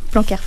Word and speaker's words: Blanquer: En France, l'école est obligatoire de 3 à 16 ans depuis juillet 2019 grâce à la loi Blanquer. Pardon, je ne sais pas si je le dis bien. – Blanquer. – Voilà. Blanquer, pardon.